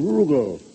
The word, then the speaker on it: Rugel: It's a charming room, Mr. Bugle. – The name's Rugel. Rhymes with Bugle.